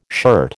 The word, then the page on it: shirt: American English